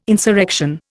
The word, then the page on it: insurrection: Indian